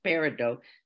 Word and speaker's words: Peridot: It's called Peridot, Peridot, but pronounced Peridot, the Virgo gemstone.